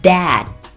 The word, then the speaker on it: dad: 大家在说这一整句话的时候还要注意开头的爸爸这个单词的发音 dad，第二个字母 d 可以停一拍不发声 dad。